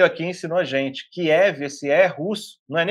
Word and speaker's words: Kiev: No iniciozinho da invasão, que foi o fantasma de Kiev, né? Falei certo? – Falou bonito, Kiev.